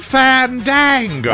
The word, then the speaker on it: Fandango: Fandango. – That's how you say it. – Fandango is your ultimate source for movie tickets and showtimes, theater listings, movie reviews, and coming attractions.